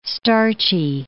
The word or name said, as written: starchy